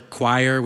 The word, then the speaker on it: choir: Or choir, which rhymes with liar, and as well as squire and fire and pyre and friar and probably a dozen more.